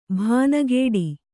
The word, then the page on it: ಭಾನಗೇಡಿ: bhānagēḍi